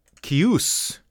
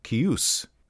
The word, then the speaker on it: Caius: But according to Forgotten Realms Wiki, it is Caius, and that lines up with the Dragon magazine number 93 as well. Caius, emphasis on the second syllable, Caius.